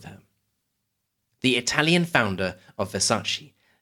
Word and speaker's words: Versace: Now, on to our final fashion house, Versace, or as it should be pronounced, Versace.